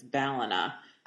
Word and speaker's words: Ballina: Oh, I know, because when I got on the plane to go to the airport, I said, "Hi, I'm going to Ballina." And the guy said, "Ballina," and sort of mocked me. And I was like, "Am I saying it wrong?" He said, "Yes, it's Ballina."